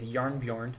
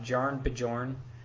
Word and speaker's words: Jarnbjorn: He has the weapon trait that says Unworthy Thor starts the game with Jarnbjorn equipped. By the way, that's how you pronounce that if you couldn't figure it out. It's not Jarn Bjorn. It's Jarnbjorn.